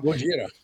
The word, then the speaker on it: Godzilla: And now there's a huge Godzilla or Godzilla or however you're supposed to pronounce it. – Godzilla. – Yeah, hit that one.